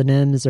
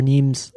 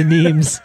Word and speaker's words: Nghiem: I started this band with my brother called The Nims, or Neems, if you're Vietnamese. If you didn't grow up in Oklahoma pronouncing your own name wrong, we're The Neems.